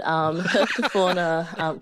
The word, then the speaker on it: herpetofauna: Help me with my pronunciation. What is it? What is your specialty? Herpetofauna? – Yeah, it does sound a little bit sus, doesn't it? Herpetofauna keeper.